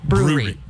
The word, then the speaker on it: brewery: It's two syllables, not three. Brewery.